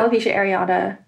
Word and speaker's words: alopecia areata: And now you've sort of come full circle to where you're attending the conference, the, how do you say, alopecia areata? – Yeah. Yeah, alopecia areata.